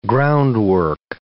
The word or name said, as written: groundwork